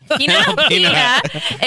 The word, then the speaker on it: Panalpina: The Spanish Y. – Pina. – Okay. – Panalpina. – DSV Panalpina, is that Panalpina expects to eliminate Panalpina colada. Like, say it like that.